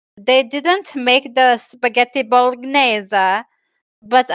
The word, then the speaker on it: Bolognese: How do you say that, Fringa? – Bolognese.